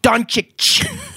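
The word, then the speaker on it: Dončić: Freaking Luka Doncic. it's spelled D O N C I C. How Don Don Kick Don Ditch. Titch. There is no T.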